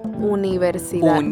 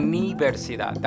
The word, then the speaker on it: universidad: Un, wow, that one always gets me. But it's not colegio. Colegio is school. – Yeah. – Univers, universidad. – Yeah. – Un. – Universidad. – Universidad. That's the part that gets me off. – Yeah. Universidad.